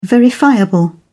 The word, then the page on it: verifiable: British English